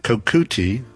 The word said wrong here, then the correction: Kokutai: Actually, I think it's pronounced Kokutai.